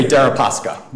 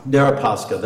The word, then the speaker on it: Deripaska: Is that a correct pronunciation? – I'm not sure, Your Honor, but that sounds correct. – I suspect you've used that name before. Could you help me out with that? – I would say Deripaska. – Deripaska, then I'll use your pronunciation.